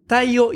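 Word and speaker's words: Theo: Tayo Jansen, se escribe Teo, mm. – pero se dice Tayo.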